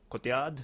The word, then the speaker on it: Cotillard: Cotillard? How do you pronounce this? – No, that's exactly how you pronounce Perfect.